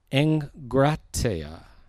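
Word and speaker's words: egkrateia: It's spelled E-G-K-R-A-T-E-I-A. E-G-K-R-A-T-E-I-A. Engratia is how it's pronounced. Engratia. It sounds a little bit, Tony said last night, like ingratiate, which is maybe a good memory tool.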